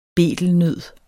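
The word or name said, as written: BETELNØD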